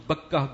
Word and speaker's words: بکہ: اس کا ایک تلفظ بکہ بھی ہے.